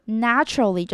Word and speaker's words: naturally: 但是呢，我们要注意到这个字呢，它有很多的变化，像是 naturally 就是自然的，变成一个副词。这个时候 A 的发音又不一样了，A 就会变成 at 的音，naturally。